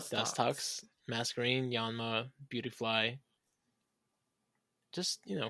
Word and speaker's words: Masquerain: The only Pokemon I know that has it is Masquerain.